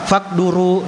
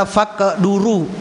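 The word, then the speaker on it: faqduru (فاقدُروا): Faqduru lahu. Ingat yang betul adalah bacanya adalah faqduru. Dalnya di dhamma, bukan dalnya di kasrah.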